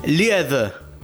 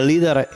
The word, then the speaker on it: Lied: Lied, pronunciado como Lied en alemán, el plural sería Lieder,